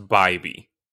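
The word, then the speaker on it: Bibee: Sunday, 23-year-old right-handed pitcher Tanner Beebe continues to deal. So hang on real quick. I got information that it's pronounced Bybee, and I would have Bybee? Never known that. Yep, it's Bybee.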